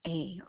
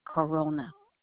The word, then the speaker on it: Corona: Corona, like the beer, Corona.